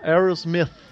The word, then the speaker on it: Aerosmith: Tá, quer que eu fale com a pronúncia certa? Aerosmith.